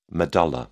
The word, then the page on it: medulla: British English